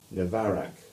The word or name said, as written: Navarac